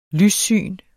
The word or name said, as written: lyssyn